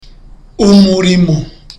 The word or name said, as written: umurimo